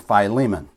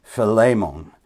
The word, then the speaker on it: Philemon: In the city of Colossae, there's a man named Philemon, or I believe it's properly pronounced Philemon, but I've called him Philemon all my life, or all my Christian life, so I'm just going to use the word Philemon because it's just natural to me.